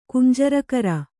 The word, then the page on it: ಕುಂಜರಕರ: kannada